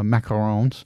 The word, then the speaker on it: Macaron: Your town is also famous for the macarons.